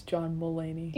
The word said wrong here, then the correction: Mulaney: Mulaney. I put too much emphasis on mule.